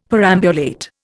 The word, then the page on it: perambulate: Indian